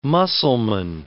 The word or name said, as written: Mussalman